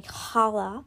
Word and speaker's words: challah: So yes, I will attempt to make some challah today, which is pronounced that way, but it looks like challah.